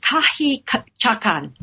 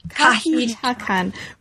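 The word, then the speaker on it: Kahiichakan: Kahichakan. Is that how, am I pronouncing it right? – It's Kahichakan. – Kahichakan, right.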